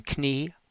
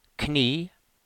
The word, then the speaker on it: Knie: The two letters in Knie are a single sound, a long E, Knie.